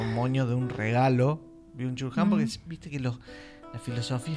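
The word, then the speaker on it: Byung-Chul Han: Byung-Chul-Han. Byung-Chul-Han. – Yo le digo Wan Chan Ken.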